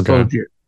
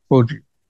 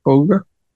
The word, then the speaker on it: Foggia: Dennis Foggia. – Fogarty? – No, Foggia. Foggia. – Spell it? – F-O-G-G-I-A.